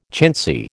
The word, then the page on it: chintzy: American English